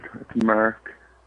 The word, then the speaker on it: Mark: Mark. – Merrick? – Must be my accent. Mark, what must be my accent? – Excuse me? – Must be my accent. It's Mark. – M-E-R-K. Please spell it. Please spell it. You should know. – M-A-R-K.